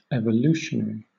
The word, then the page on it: evolutionary: British English